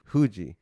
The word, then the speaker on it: Fuji: So you don't say Mount Fuji. How do you pronounce it? – Mount Fuji. – Fuji. Okay. So that's how the Japanese pronounce it. It's mm-hmm. – Fuji.